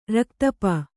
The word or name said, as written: ರಕ್ತಪ